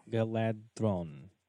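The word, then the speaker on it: Galadhon: Did I say that right? – Galadron. – Galadron. – Yeah. It's that DH makes that the sound.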